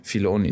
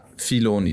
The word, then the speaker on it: Filoni: Filoni, ne? So spricht man den aus, weil ich habe es aufgeschrieben. Ja, Filoni, Dave Filoni.